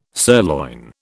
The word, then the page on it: sirloin: British English